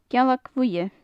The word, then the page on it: gealag bhuidhe: gaelic